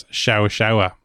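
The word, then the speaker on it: Seabhá-Seabhá: And a Chow Chow in Irish is Shawa Shawa. And that is spelled S-E-A-B-H-A hyphen S-E-A-B-H-A.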